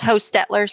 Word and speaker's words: Hochstetler: Hi, it's Hochstetler. So the CH is silent.